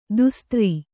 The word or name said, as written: ದೋಸ್ತಾರ